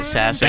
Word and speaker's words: Sasser: is that how you pronounce it? Sasser? – That sassy worm? – Sasser. – Sasser, yeah.